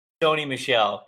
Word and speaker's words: Sony Michel: And did I pronounce his name right? Is it Sony or Sonny Michel, or is it Sony Michael? – Sony Michel, kind of a combination of both of those that you just said.